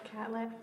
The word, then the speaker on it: Catlett: Elizabeth Catlett.